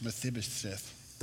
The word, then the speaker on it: Mephibosheth: It's Mephibosheth.